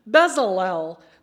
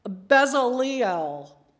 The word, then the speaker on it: Bezalel: "The Lord said to Moses, 'See, I have called by name Bezalel.'" Some versions say Bezaleel with that extra syllable in there, but in mine it's Bezalel, "the son of Uri, the son of Hur, of the tribe of Judah.